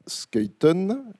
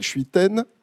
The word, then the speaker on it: Schuiten: Alors, la présentation de, alors pour vous c'est de droite à gauche, donc François Skuyten, j'ai la bonne prononciation? Voilà. – Oui, ça va. – Non, parce que non. La majorité des gens présents dans cette salle, très probablement, vous appellent Schuiten, moi-même, bon.